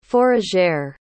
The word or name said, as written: fourragère